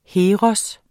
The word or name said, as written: Heroer